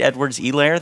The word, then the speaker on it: Edwards-Helaire: And I did learn that, I guess, we're not pronouncing the H, Clyde Edwards-Helaire, they kept saying on the broadcast, which, you know, I'm into it.